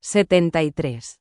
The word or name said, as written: 73